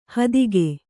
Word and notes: ಹಾದಿಗ: hādiga

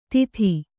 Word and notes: ದಿತಿ: Kannada

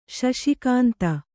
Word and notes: ಶಶಿಕಾಂತ: śaśi kānta

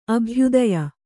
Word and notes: ಅಭ್ಯುದಯ: abhyudaya

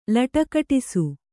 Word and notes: ಲಟಕಟಿಸು: laṭakaṭisu

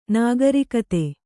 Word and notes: ನಾಗರಿಕತೆ: nāgarikate

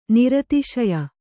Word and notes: ನಿರತಿಶಯ: niratiśaya

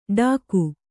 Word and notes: ಡಾಕು: Kannada

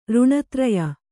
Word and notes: ಋಣತ್ರಯ: kannada

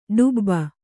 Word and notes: ಡುಬ್ಬ: ḍubba

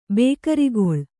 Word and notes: ಬೇಕರಿಗೊಳ್: Kannada

